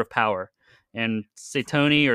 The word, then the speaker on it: Satony: S-A-T-O-N-Y. Satoni.